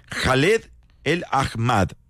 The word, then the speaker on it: Khaled Al-Ahmad: Khaled Al-Ahmad, realmente se pronuncia.